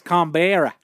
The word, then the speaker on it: Canberra: It's pronounced Canberra.